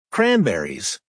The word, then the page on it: cranberries: American English